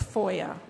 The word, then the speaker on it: FOIA: Now, starting in the 60s, the federal government in 1966 passed its open records law, Freedom of Information Act, FOIA is how it's pronounced.